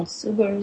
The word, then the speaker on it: Subaru: Another friend of ours, she ordered a Subaru car, the Subaru, and hope I'm pronouncing that correct, right? Subaru? – Subaru. – Yes, you got it.